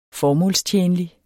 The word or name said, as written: formålstjenlig